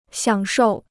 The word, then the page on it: 享受: xiǎng shòu